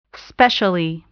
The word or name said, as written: specially